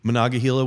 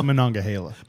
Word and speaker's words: Monongahela: And so Monongahela was 100% rye, typically 95, 100% rye mash bill.